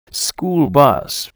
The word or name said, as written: school bus